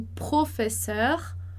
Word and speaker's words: professeur: Aqui um outro detalhe, a gente vai ter professeur, esse som de er, que vai ser o mesmo som de chauffeur e vai ser o mesmo som de coiffeur.